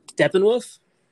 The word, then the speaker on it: Steppenwolf: Steppenwolf? – Okay.